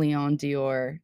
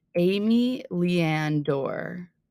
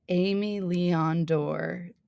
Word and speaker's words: Aimé Leon Dore: No, I always, every time, even in my mind, I say Dior instead of door for M. Leon Dior. What? That's crazy. Let me see how it's actually pronounced because I know the brand, of course, and I want to be, I want to know how, I, how to say it so that people respect me. Amy Leanne Dorr. Okay, that is not what I was expecting. Let's hear it. Aimé Leon Dore.